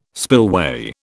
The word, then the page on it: spillway: British English